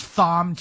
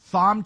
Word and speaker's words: Thom: Here's Thom Tillis. I'm putting the H back in. No one pronounces the H. I'm pronouncing the H in the Marlo Show. Thom Tillis, cut 13, go.